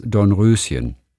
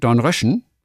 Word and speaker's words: Dornröschen: Das um des Reimes willen bitte in der zweiten Silbe ausgesprochen wird, wie die Mehrzahl von Frosch. Frosch, Frösche. Dornröschen.